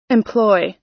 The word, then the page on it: employ: American English